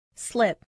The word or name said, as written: slip